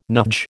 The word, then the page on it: nudge: American English